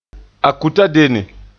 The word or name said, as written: akutadenne